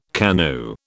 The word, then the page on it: KANU: British English